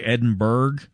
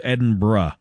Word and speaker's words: Edinburgh: It breaks my American brain because I look at that word and I want to say Edinburgh, like Pittsburgh, but I believe the word is Edinburgh, which feels dirty and wrong.